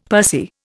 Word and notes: pursy: Indian

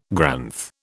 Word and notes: Granth: British English

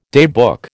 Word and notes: daybook: American English

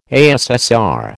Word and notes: ASSR: American English

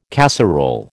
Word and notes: casserole: American English

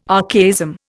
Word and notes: archaism: Indian

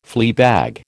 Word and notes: fleabag: American English

